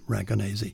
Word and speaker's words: Ragonese: He's half Italian, based in Los Angeles, so I guess he does pronounce it the Italian way, Ragonese.